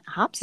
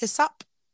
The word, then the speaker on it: hyssop: Hops? – Hyssop. – Hyssop.